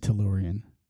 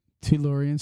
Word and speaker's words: Tellurian: Did you say a DeLorean? – No. No, I know you said Tellurian. Tellurian.